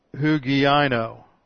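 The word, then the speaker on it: hugiano (ὑγιαίνω): And this second word is the word hugiano. It's both of these words, huodao and hugiano, are infinitives of result. And this word is spelled like this, hugiano. H-U-G-I-A-N-O.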